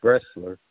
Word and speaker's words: Bressler: This is Mike Bressler.